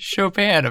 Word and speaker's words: Chopin: It is pronounced Chopin, apparently. – It's pronounced Chopin, yeah. Not Chopin. – Oh, it's because he's French, so it's not Chopin.